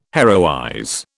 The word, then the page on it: heroize: British English